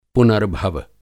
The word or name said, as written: पुनर्भव